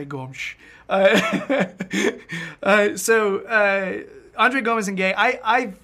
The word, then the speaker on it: Gomes: André Gomes. Gomes. Apparently, that's the Portugal Portuguese pronunciation. Now, if you're Brazilian Portuguese, it's just Gomes. Gomes. But Gomes. No one's doing it right.